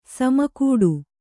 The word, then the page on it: ಸಮಕೂಟ: sama kūṭa